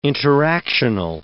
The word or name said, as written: interactional